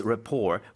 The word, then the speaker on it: rapport: No, it's French and that's why you do not hear the T. It's R-A-P-P-O-R-T. But we say rapport. – Rapport. – Like T is non-existent.